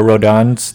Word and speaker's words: Rodin: Rodin or Rodin's the thinker? – Rodin.